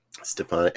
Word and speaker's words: Stefanik: Recommended by Stefanichek, I know I'm saying that wrong, for her seat. – Stefanik.